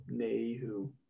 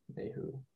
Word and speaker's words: Nayhoo: Yeah, and the song's called, or it's spelled N-A-Y-H-O-O. – Nayhu. – Nayhu. Yeah. – Yeah. – It's a little tricky one.